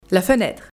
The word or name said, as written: la fenetre